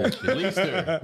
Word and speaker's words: Leicester: Leicester. I called it Leicester. – That's terrible, man. – Little sister. Leicester Square Theatre.